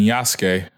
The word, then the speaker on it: Yasuke: You didn't mention Yasuke.